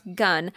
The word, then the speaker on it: gan: The word gun, but it's spelled G-A-N, so it's G-A-N, pronounced gun, is used as a collective noun.